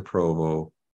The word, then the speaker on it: Provo: And I remember that I saw your journal or diary where you had drawn out the, before you got to Provo, what the house was going to look like that you're going to live in.